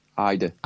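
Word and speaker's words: Ayda: Is it Ada or Ida? – Ida.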